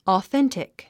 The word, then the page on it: authentic: ɔːθéntik; オーセンティック